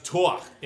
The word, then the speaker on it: Thor: It's pronounced Thor in Danish, actually.